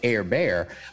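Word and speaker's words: Justin Herbert: You know, Justin Air Bear, it's spelt Herbert, but pronounced Air Bear.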